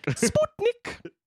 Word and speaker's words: Sputnik: Did I pronounce that right? – Yes, it's pronounced Sputnik. – Sputnik!